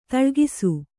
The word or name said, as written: ದಾಲಕ್ಷ